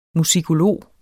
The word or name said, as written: musikolog